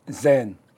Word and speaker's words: 禅: 日本的禅宗它的读音呢是读 Zen，好近似粤语，点解啊？